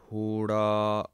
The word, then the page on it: hora: Punjabi